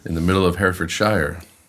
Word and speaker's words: Herefordshire: And yet I think you're pronouncing it really quite well for an American. they struggle a little bit with Herefordshire, but you're doing a very good job.